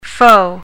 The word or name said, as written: faux